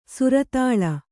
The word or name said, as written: ಸಿರತಾಳಿ